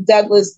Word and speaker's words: Douglass: My name is Michelle Carter hyphen Douglas, D O U G L A S S.